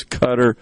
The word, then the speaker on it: Qatar: By the way, pardon me for mispronouncing Qatar. I can't believe I was doing that, but I pronounced it Qatar because that's the way it's spelled, and my mind was just not thinking. I've heard it a million times. I know it is pronounced as Qatar, and somebody on the ceasefire text line actually reminded me of that.